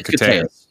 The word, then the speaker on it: Koteas: Koteas, yeah.